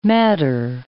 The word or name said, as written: madder